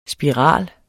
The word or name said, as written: spiral